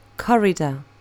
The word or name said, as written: corridor